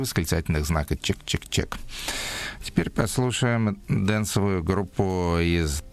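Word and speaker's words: !!!: То есть она так и называется, произносится это как чик-чик-чик, а так печатается как 3 восклицательных знака.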